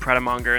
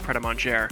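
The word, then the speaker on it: Pret A Manger: Saw my first pret-a-manger, called it pret-a-monger, and Sarah, who is the love of my life and the person with whom I'm in a monogamous relationship, told me it's pronounced pret-a-manger.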